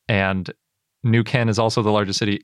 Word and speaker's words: Neuquén: and I think I've been pronouncing Neuquén wrong. It's spelled N E U Q U E N with an accent over the E. – Yeah, but I found a pronunciation guide that said New Ken is how you say it.